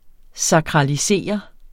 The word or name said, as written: sakralisere